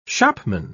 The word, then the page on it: shopman: British English